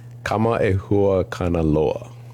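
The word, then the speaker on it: Kamaehua Kanaloa (Kamaʻehuakanaloa): I apologize if I butcher it, but it's Kamaehua Kanaloa. – That is exactly right.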